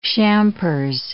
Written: champers

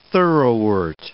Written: thoroughwort

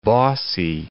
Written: bossy

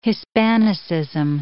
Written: Hispanicism

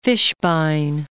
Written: Fishbein

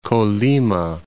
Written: Colima

